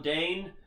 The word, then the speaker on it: Dane: And I'm Dane. Everett the Tarnished is my name. Rhymes. – It rhymes. – Because Dane and name rhyme.